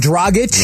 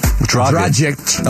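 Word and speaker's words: Dragić: Give me former son Goran Dragic. – Yeah, that was the one I was considering as well. Although some people even locally pronounced it Dragic. – That was tragic the way that was pronounced.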